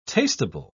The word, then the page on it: tastable: British English